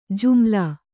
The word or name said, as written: ಜುಮಲಾ